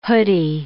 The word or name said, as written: hoody